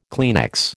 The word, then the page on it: Kleenex: American English